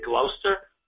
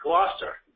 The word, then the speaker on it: Gloucester: Did you say Gloucester? – That's how I pronounce it. Yes, it was. – Just to make sure, you are from this area, right? It's Gloucester. – Gloucester. I apologize.